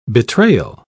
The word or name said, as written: betrayal